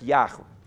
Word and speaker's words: Яхве: то большинство сегодня богословов и верующих сходится на том, что, скорее всего, это произносится как Яхва.